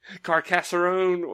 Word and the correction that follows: Carcassonne: Wait, Carcassonne. Yeah, Carcassonne. Yeah, that's the correct way to pronounce it.